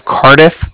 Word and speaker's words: Cardiff: That's N-I-K-O. And then the Bank of Cardiff is spelled C-A-R-D-I-F-F, as in Frank Frank. So it's like car and a different.